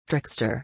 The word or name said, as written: trickster